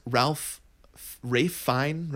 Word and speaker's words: Fiennes: It stars Ralph Fiennes, right? Is that how you pronounce it? – Oh, yeah.